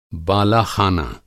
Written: बालाख़ाना

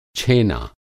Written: छेना

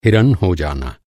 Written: हिरन

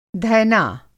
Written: धैना

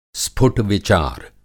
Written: स्फुट-विचार

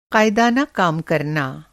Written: क़ाइदाना

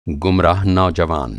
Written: गुमराह

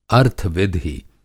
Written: अर्थ-विधि

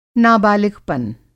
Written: नाबालिगपन

